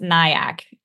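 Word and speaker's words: NAIAC: So first of all, to me, I couldn't be any more sad that it's pronounced NIAC, which I just, of all acronyms, come on. That's NIAC. It's a town in Long Island, isn't it? – The silent A.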